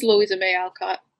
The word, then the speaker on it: Louisa May Alcott: Louisa May Alcott's green tea Brit. I probably butchered that. English major, help me. – No, it is Louisa May Alcott.